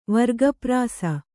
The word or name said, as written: ವರ್ಗಪ್ರಾಸ